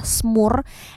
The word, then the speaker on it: smoor: Jadi kalau nama Belandanya itu adalah smoor, S-M-O-O-R gitu ya, tapi kalau di Indonesia ejaannya S-E-M-U-R, semur ya kan.